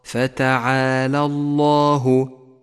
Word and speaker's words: الله: لاحظ بعدها اللام المفخمة في لفظ الجلالة: فتعالى الله، فتعالى الله، ودقق بين نطق اللام المرققة في كلمة فتعالى واللام المفخمة في كلمة الله، فتعالى الله.